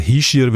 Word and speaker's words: Hischier: Wir haben den Vorzeige-Hockeyaner, den Ishier. Habe ich es richtig gesagt das mal? – Oder wie sagt man? Ich sage auch Ishier. Richtig muss man glaube ich sagen Hischier, weil er ein Oberwalliser ist.